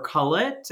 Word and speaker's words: cullet: So how does that bottle of wine then become sand or cullet? Did I say it right? And what is cullet? – Yeah, so